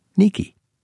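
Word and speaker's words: Nike (Νίκη): At this temple, she was worshipped for bringing the Athenians victory, that is, Nike, or as Greeks pronounce it, Nike.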